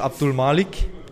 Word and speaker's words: Abdulmalik: hey, wie spricht man deinen ganzen Namen aus? Also hast du einen Spitznamen? Magst du deinen ganzen Namen mal sagen, deinen Spitznamen? Also, mein ganzer Name ist Abdul Malik.